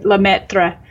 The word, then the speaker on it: Lemaître: Lemaître.